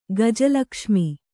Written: ಗಜಲಕ್ಷ್ಮಿ

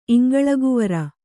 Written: ಇಂಗಳಗುವರ

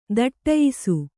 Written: ಥಟ್ಟಯಿಸು

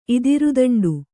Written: ಇದಿರುದಂಡು

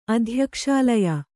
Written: ಅಧ್ಯಕ್ಷಾಲಯ

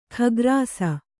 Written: ಖಗ್ರಾಸ

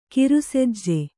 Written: ಕಿರುಸೆಜ್ಜೆ

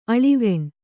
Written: ಆಳವಿಣೆ

